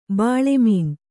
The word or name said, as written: ಬೆಳುಮೀನ